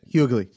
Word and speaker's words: Hughley: Hughley.